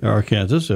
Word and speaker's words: Arkansas: Learned how to say the proper pronunciation of the Arkansas River. – Arkansas, yeah.